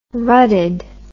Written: rutted

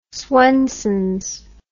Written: swensens

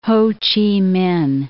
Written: Ho Chi Minh

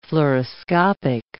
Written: fluoroscopic

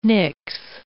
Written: nix

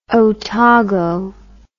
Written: otago

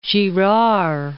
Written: Girard

